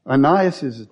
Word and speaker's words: Aeneas: He turns your attention, Aeneas, I guess is the correct way to say it, his attention back to Jesus.